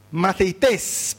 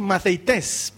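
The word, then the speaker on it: Maceites (μαθητής): La palabra griega que define al discípulo se pronuncia Maceites, ¿pueden decir eso? Maceites, ¿pueden decir? Maceites, eso es griego, ¿sí? Maceites, eso es discípulo.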